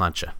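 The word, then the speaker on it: sláinte: Cheers, they go slaint, which is S-L-A-I-N-T-E. – This guy's pronounced slantcha. – What does it mean? – Cheers. – That just means cheers? – Yeah, it's like what you say. It's Gaelic for Irish for cheers.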